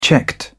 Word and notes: checked: British English

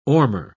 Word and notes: ormer: British English

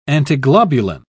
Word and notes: antiglobulin: British English

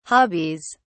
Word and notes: hobbies: British English

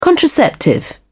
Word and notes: contraceptive: British English

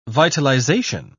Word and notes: vitalization: British English

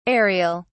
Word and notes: areal: British English

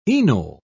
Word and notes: enol: British English

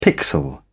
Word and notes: pixel: British English

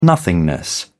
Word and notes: nothingness: British English

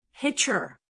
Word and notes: hitcher: American English

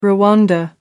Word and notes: rwanda: American English

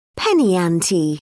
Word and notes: penny ante: British English

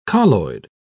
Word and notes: colloid: British English

